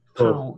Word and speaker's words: Pau: I believe, is it pronounced Pau? I can never get this right. Pau or Pau? – Pau.